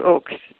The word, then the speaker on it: Ochs: Can you tell us about O-C-H-S, where it comes from, that spelling of Oakes? – Well, that spelling, it is the German spelling, and I suppose it would have been pronounced Ox. But when that family came to the United States, they changed it to Oakes pretty quickly.